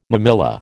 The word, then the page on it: mammilla: American English